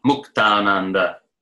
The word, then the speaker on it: Muktananda: Notice all the Swami names are incorrectly pronounced by Westerners, right? Muktananda, Yogananda, right? But it's Sandhi, Mukta, Ananda, Muktananda, Yoga, Ananda, Yoga, Ananda.